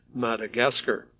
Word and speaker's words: Madagascar: Despite the spelling of Madagascar, it sounds like they just pronounce it in Malagasy, Madagascar.